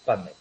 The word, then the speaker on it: PubMed: Bueno, pues fijaros, la base de datos que solemos utilizar en este campo, la que más utilizamos en investigación, es PubMed, ¿vale? Se escribe PUB como de público, ¿vale? De publicación. PUB como en PV, como las tabernas inglesas estas, y Med de medicina, PubMed.